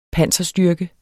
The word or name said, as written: panserstyrke